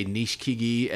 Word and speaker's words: Nishikigi: You know how the Japanese kind of truncate some of those vowels. You say Nishikigi, and then you hear other guys pronounce every vowel. So it's Nishikigi.